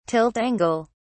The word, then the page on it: tilt angle: British English